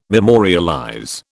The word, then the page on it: memorialize: British English